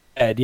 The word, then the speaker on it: Zedd: Zedd, yeah.